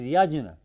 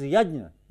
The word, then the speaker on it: yajna: Both pronunciation is there. The correct pronunciation is yajna. It has become bad here also. They call it yajna. The right one is yajna, yajna.